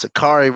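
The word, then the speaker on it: Sha'Carri: I don't want to say her name wrong. Miss Richardson. That's what I would call it. Yeah, it's Akari Richardson.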